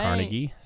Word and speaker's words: Carnegie: Carnegie, at age 17.